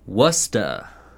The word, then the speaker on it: Worcester: It looks like Worcester, man. – It does, but they don't say it like that because Massachusetts says things dumb. – Worcester. – And I actually have, I have online here, Wusta, from my stepmother Joanne, who is from Concord.